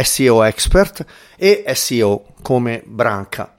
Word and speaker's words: SEO: E quindi quando stiamo parlando di esperti SEO o professionisti SEO, in inglese non si dice CEO, perché CEO è quell'altra cosa, ma si dice SEO expert.